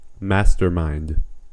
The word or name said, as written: mastermind